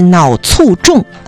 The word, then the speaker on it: 脑卒中: 其实这个脑卒中啊，是大家平时的一个念法，它的标准读音应该是脑卒中。所以为了大家能够听明白啊，我先给大家按照大家习惯的念法念一下，再把这个音呢标准的读音教给大家。大家平常说的脑卒中